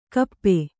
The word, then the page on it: ಕಪ್ಪ: kappa